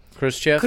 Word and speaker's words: Khrushchev: Khrushchev.